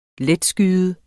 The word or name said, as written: letskyet